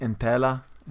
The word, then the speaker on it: Entella: Is that right, Entella? Is that how you say it? – Yeah, I mean, you say it a lot better than I do. So, yeah, you're pretty spot on.